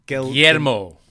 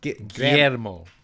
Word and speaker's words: Guillermo: Guillermo. – Guillermo.